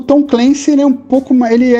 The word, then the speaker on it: Clancy: É, não sei se a pronúncia é essa, é Clancy, né? Tom Clancy.